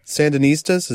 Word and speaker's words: Sandinistas: Sandinistas, is that how you say it? – Uh-huh. Sure.